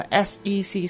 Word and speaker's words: SEC: 我哋逢亲见到 SEC 三个字 capital 嘅，咁你就要即指翻佢系要读三个 letters，就唔好读嗰做 set。